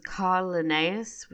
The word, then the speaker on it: Carl Linnaeus: Uh, the scientist name dropped in this episode was Carl Linnaeus, which I think is how you pronounce it.